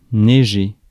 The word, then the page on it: neiger: France; [nɛ.ʒe]